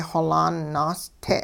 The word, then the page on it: xolaan naaste': hupa